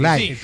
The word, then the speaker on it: Live: La dije bien, Live. Se escribe Taquilla Live. – Live con V. – Pero en Taquilla Live se pronuncia así.